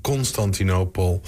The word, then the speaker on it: Constantinopel: Werd het nummer geschreven, Constantinopel. Zeg ik het goed? Ja, ik zeg het helemaal goed.